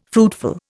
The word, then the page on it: fruitful: Indian